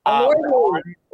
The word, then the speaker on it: Elordi: Kaya Gerber and Jacob Elordi. – Elordi. – A vacation with Kaya. – Jacob Elordi. – Jacob Elordi. – God, sorry. Maybe once he gets more famous, I'll say it right.